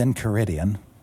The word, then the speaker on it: Enchiridion: So it's called Volos in Caridian. – In Caridian. – Yeah. – In Caridian. It's incredible. – Enchiridion is sort of how it's spelled.